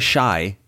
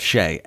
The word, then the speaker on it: Shai: You know, that actually helps me because there's been times on this podcast and in life where I say Shai instead of Shay, and I could actually use some sort of like wordplay to lock it in how it's pronounced, and Shai Gay gets me there. Shai, like Shay.